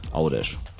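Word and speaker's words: Għawdex: Dann ein W, das sich U ausspricht. Dann haben wir Au. D und E bleiben, haben wir Au, D. Folgt zum Schluss ein X. Da haben wir gelernt, das ist ein Sch. Ausgesprochen, macht aus Gavdex Audesh.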